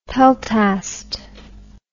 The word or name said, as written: peltast